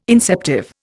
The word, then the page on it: inceptive: Indian